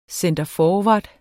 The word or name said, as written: centerforward